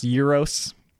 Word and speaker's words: gyro: By the way, I know it's pronounced euros. I just call it a gyro because it's funny. And then when I was living in New York, everybody called it a gyro.